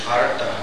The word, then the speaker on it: artha: And then the seeker of wealth, artha, that's with a short A, the TH, artha, arti. Artha, arti, the seeker of wealth. Artha, and artha, artha, and Arta and Artha Arti. Get that aspirate in there.